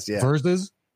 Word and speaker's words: Verzuz: Verzuz. They're trying to be cool and hip, so they make the S's Z's.